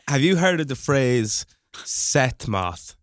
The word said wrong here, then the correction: sesh moth: Sesh moth. Sorry, I've said that wrong.